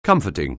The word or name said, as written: comforting